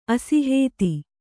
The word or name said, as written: ಅಸಿಹೇತಿ